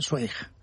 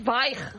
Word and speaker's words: Zweig: Tú sabes que yo toda mi vida he estado diciendo que yo leía Stephen Zweig. – Ah, claro. Eso no. Es Zweig.